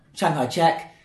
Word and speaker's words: Chiang Kai-shek: Chiang Kai-shek.